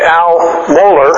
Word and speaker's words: Mohler: We have a very fine CD that was done at the Ligonier conference that some of us went to in Orlando by Al Moyer. Did I say it right? Al Mohler, president of Southern Seminary.